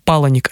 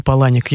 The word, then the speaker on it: Паланик: И эта книга, наверное, для тех, может быть, кому отчасти нравится творчество Чака Паланика. Может быть, мне кажется, они с Пелевиным чем-то похожи, но, на мой взгляд, Пелевин гораздо сильнее. – Если позволите, вот точно, что я знаю, что Паланик, а не Паланик.